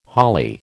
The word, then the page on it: holly: American English